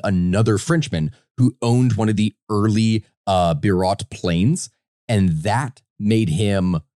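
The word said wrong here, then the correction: Blériot: I'm probably even saying the name incorrectly. Birot? I think it's Birot, right? Would that be because it's French? – There's a L in there that you haven't pronounced once.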